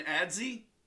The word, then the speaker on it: adze: Number 13, I call it an adze. Other people have pronounced it differently. It's spelled A-D-Z-E.